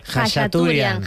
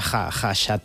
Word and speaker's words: Khachaturian: Khachaturian. – Khachaturian. – Aram, se escribe K-H-K-H-A, pero se pronuncia Khachaturian.